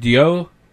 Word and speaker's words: Ryo: It's spelled R-Y-O. And Ryo, but a lot of people call it Dio. – Or Ru. Ru.